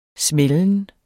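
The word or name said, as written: SMÆLDEN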